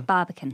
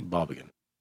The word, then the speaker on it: Barbican: Did I say it wrong? Barbican? – Barbican. – Barbican.